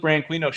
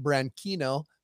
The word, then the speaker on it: Branquinho: And I always thought it should be pronounced Branquinho because you don't say mosquito, but it's not Spanish, right? – Oh, it's not Spanish. – It's Portuguese.